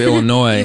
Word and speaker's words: Illinois: Yeah, and you don't pronounce the S, so it's University of Illinois.